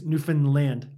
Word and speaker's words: Newfoundland: Not Lind. I think it's Newfoundland.